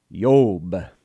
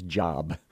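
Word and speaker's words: Yob (איוב): And by the way, his name in Hebrew is Yob, with a Y sound and a long O sound. And that will help not get confused and pronounce his name as Job, which I've heard some people say.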